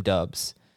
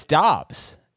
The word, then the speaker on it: Doubs: And he had to correct it because all throughout his college career, his coach called him Dubs. But, and even the pronunciation guide apparently said Dubs. – Yes. I knew I was going to get it wrong. – But it's Dobbs.